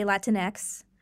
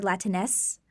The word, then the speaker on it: Latinx: There are two different ways to pronounce it, actually. Most people will say Latinx. And then I've also heard Latinx, like pronouncing the X like an S.